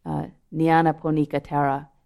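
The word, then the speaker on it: Nyanaponika Thera: So just listen to the way the Theravadan monk Nanyan Ponika Thera, I'm not saying his name right, Nyana Ponika Thera, describes these Brahmaviharas.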